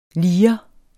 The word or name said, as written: lir